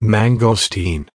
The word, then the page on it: mangosteen: American English